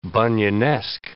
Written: Bunyanesque

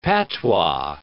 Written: patois

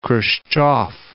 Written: Khrushchev

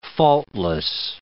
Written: faultless

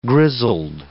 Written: grizzled

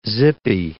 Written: zippy

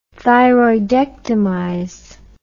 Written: thyroidectomize

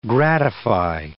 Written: gratify

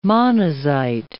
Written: monazite

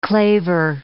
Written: claver

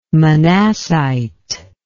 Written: manassite

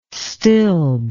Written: stilb

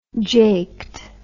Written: jaked

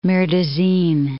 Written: Marais des Cygnes

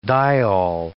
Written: diol

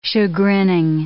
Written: chagrining

